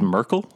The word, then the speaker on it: Myrkul: So that was Merkel? What's his name? – Yep. – Oh, okay. – M-Y-R-K-U-L. But pronounced, I wrote it down like I Angela Merkel.